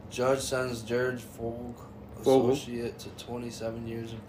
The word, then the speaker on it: Fogle: Fogle.